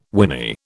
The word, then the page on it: whinny: British English